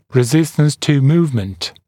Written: resistance to movement

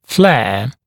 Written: flare

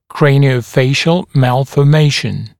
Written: craniofacial malformation

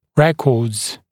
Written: records

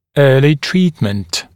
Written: early treatment

